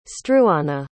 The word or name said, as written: struana